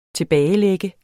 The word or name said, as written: TILBAGELAGTE